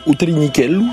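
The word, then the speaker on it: U Trinichellu: C'est un tout petit train qui s'appelle Utelinichello. Je me permets de le prononcer comme ça, puisque j'ai moi-même une maman en Corse.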